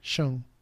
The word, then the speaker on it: Schon: Pero se escribe así Sean. No, Neil, Neil, S-C-H-O-N. Sean. S-C-H-O-N. Ah, Neil Sean.